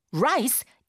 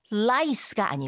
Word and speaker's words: rice: Rice is usually harvested in September. Rice is usually harvested in September. Rice가 쌀을 가리키거든요. 이거 lice가 아니고 ra-ra-ra rice라고 합니다.